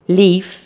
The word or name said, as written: leaf